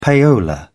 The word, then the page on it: payola: British English